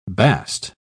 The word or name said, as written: bast